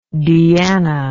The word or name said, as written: deanna